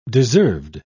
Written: deserved

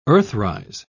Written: earthrise

